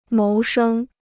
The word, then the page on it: 谋生: móu shēng